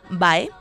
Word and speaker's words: bae: Bueno, y, eh, bueno, pues otra contracción sobre la que ocasionalmente tenemos confusiones con su procedencia es la expresión vae, escrito BAE, ¿eh? pronunciado BEI, que